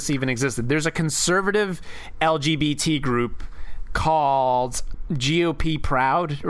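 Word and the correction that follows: GOProud: or I don't know how you'd pronounce it, but it's GOP and then Roud. So Jop. – GO Proud. Yeah. – GO Proud. There you go.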